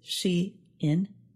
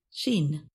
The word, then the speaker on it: Shein: Online fast fashion business Shein is Shein, not Sheen.